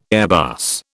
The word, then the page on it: Airbus: British English